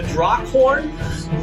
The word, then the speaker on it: Draakhorn: Uh, there is also the Draakhorn. Uh, let me make sure I'm pronouncing that correctly. Yes, the Draakhorn, which would be calling these dragons to to this place.